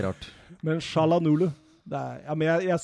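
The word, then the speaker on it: Çalhanoğlu: Hakan Shalanolo der, ja. Er det ikke det han heter? Jo, jo, jo.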